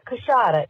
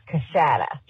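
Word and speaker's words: Coushatta: But the proper way to say it is not Cushada, it's Cushada.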